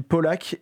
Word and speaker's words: PLK: Bah, justement, on va écouter du hip-hop, un artiste qui s'appelle Polak, ça s'écrit P-L-K, mais ça se lit Polak,